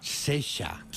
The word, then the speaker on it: Saoirse: Y entonces Begoña escucha ya cómo se dice Saoirse, que mira que en inglés, y pone aquí Sesha.